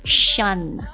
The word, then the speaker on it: shun: 第一个单词的话，你看一下是不是像我们的拼音这个顺利的顺啊，但是不能够读成顺啊，它是 shun，这是一个动词啊， shun， shun，动词表示避开、避免、回避的意思。